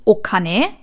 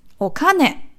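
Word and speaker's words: Okane (お金): Nicht Okane, also haha, sondern haha. – Okane. – Okane. – Okane. – Betonung ist auf dem K. – Okane. – Das E ist noch so ein bisschen dabei. – Okane. – Also nie das O betonen, sondern das, was danach kommt.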